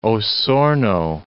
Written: Osorno

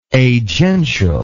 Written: agential

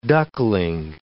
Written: duckling

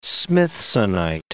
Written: smithsonite

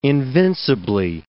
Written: invincibly

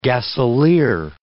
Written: gasolier

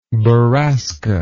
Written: borasca

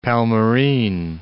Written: Palmyrene

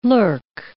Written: lurk

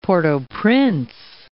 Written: Port-au-Prince

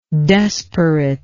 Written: desperateness

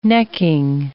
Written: necking

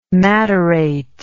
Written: matterate

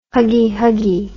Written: huggy huggy

